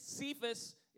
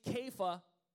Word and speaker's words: Kepha: Okay, we pronounce it Cephas. It's actually, if you want to be, you know, correct, it's actually Kepha, okay? That's how you pronounce it in ancient Greek, okay?